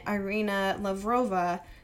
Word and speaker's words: Irina Lavrova: Another resident, Irina Lavrova, said she, I feel like I pronounced that well. – Sounded good.